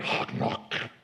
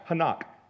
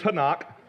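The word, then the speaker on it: chanak: But for me to come out here and go, it is the word hock knock. Anybody impressed? Or let me say it, it's pronounced hanak.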